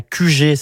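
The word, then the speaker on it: Kujé: En Ille-et-Vilaine, vous pouvez en découvrir d'autres grâce à QG. Ça s'écrit K-U-J-E accent aigu.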